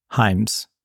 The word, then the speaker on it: Hyams: And obviously, we've talked about Hard Target with John Woo and Sudden Death and Time Cop are both Peter Hames. – So, so uh, wait, uh, are we going to say Hames or Hyams? Hyams. Okay.